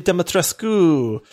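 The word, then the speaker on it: Dimitrescu: But she was from Romania, and so she actually pronounced the U. Now, she said it with a heavy accent, but she would say, oh, yes, Lady Demetrescu.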